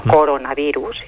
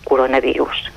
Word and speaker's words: coronavirus: Bé, doncs recordem coronavirus, una paraula composta, dues síl·labes, corona i virus, i per tant eh, ho hem de pronunciar així. Eh? No coronavirus, sinó coronavirus.